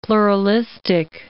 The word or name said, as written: pluralistic